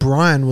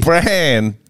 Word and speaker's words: Bran: What the writers of Game of Thrones didn't want to tell you is that Brian was actually... – Bran!